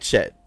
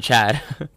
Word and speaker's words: Chet: I like Chet. No, you're right. You said it right. – I think I said Chad.